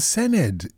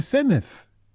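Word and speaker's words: Senedd: Just the fact that it's the Sened with a th, not Sened with a hard D. – It's not a Sened, it's the Seneth. – Yeah, exactly. – I thought you said it's pronounced just as it's written. – It's the double D, that creates a th sound.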